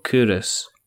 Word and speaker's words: Culross: There's you know there's a place in there's a place along the road from me called Curis, yeah. Mm-hmm. And it's it's spelled C U L R O S S, Culross.